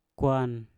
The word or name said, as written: kwan